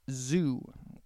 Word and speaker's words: Zhu: That is by a DJ called Zoo, which is not spelled like the animal prison. It is spelled Z-H-U.